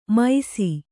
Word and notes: ಮೈಸಿ: maisi

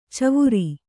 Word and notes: ಚವರ: cavara; kannada

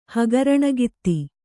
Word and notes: ಹಗರಣಗಿತ್ತಿ: kannada

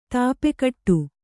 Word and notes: ಧೂಪಘಟ: dhup`pa ghaṭa

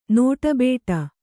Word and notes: ನೋಟಬೇಟ: nōṭa bēṭa

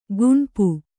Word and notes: ಗುಣುಪು: guṇupu

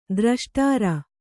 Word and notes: ದ್ರಷ್ಟಾರ: Kannada

